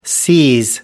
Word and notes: seize: American English

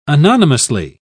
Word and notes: anonymously: British English

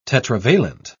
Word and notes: tetravalent: British English

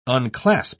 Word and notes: unclasp: British English

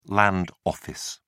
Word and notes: land office: British English

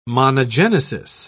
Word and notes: monogenesis: British English

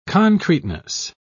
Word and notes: concreteness: British English

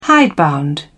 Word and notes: hidebound: British English

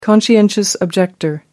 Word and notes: conscientious objector: American English

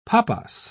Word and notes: papas: British English

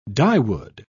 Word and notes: dyewood: British English

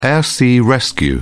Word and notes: air sea rescue: British English

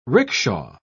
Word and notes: ricksha: British English

